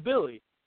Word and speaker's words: Willy: Even though it's spelled Willy, apparently it's Billy.